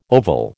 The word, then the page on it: oval: American English